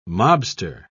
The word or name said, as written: mobster